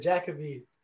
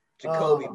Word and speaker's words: Jacoby: And I really love the part when I think it was Jacoby. – Jacoby, buddy. – Jacoby. I knew I said that wrong.